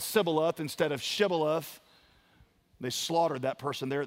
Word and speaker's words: Shibboleth: And when they said no, they would say to him, then say the word Shibboleth. And he would say, Sibboleth. Because he could not pronounce it right.